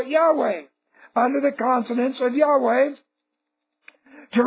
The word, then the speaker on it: Yahweh: Some religious scholars suggest that the name was pronounced Yahweh.